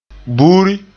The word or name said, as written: buuri